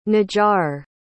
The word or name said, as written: najjar